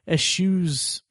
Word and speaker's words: eschew: Echoing what I said about Boyhood to a far lesser degree, it kind of eschews. Is that the right pronunciation? – Eschew.